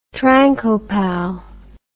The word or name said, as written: trancopal